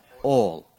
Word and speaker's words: oil: The word O-I-L, how do you pronounce it? If you're from Appalachia, it's one syllable, all. And those who are from Appalachia have been blessed by the Lord, and that is the correct way to say it.